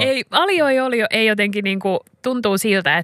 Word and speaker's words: aglio e olio: Aglio, meaning garlic. In Italian, olio. Aglio e olio.